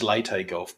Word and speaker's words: Leyte Gulf: I think it's Leyte Gulf, by the way.